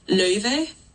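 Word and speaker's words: Laufey: But, you know, it's two vowels that are just don't exist in English. So, like, in true, like, clean Icelandic, it's pronounced Löyve.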